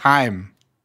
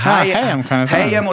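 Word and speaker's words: HAIM: Weil Jimmy Fallon sagt Heim. – Hi, aber es ist auf jeden Fall was Zweisilbiges draus geworden. Also Hayem oder Hayem. Auf jeden Fall sagt sie diese zwei Silben.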